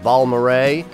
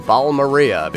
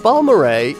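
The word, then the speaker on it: Balmorhea: The last time we heard a full-length album from the Texas band called Balmoray, it was 2012, and this is what the band sounded like, and I was mispronouncing their name, Balmaria, because that's what it looks like. But Balmoray is back now with a new album.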